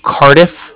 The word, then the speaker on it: Cardiff: The Bank of Cardiff. And that's spelled C-A-R-D-I-F-F, as in Frank Frank, so it's like car and then different. – Okay, and what was your name?